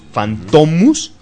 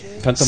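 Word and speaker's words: Fantômas: Pero fíjate que el Fantomus... Con el acento, digamos, en la U, Fantomus, Fayard lo lee mal y le gusta el nombre y dice, Fantomas. Se ve bien Fantomas con acento en la O.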